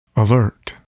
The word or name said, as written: alert